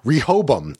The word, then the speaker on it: Rehoboam: because this company Incite created this AI thing called the Rehobome. Is that how you pronounce uh, it? – Uh, Rehoboam. Okay.